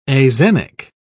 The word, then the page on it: axenic: British English